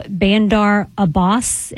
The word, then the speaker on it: Bandar Abbas: Bandar Abbas, if that's how you say it.